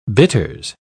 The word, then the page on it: bitters: British English